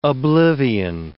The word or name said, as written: oblivion